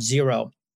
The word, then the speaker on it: Xero: but it's a business tax software company called Xero. It's spelled X-E-R-O.